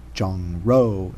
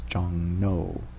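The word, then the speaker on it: Jong No (종로): For example, like a street is actually like R-O, row, but you pronounce it no, so it's not Jong Ro, it's Jong No.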